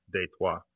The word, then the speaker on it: Detroit: You know, and now that I'm thinking about it, Detroit, I mean, that's French, because technically it's Détroit. You know, that's the pronunciation of it. – But I did not know that. – Yeah, and a lot of the streets in Detroit are French as well, but we just say it in...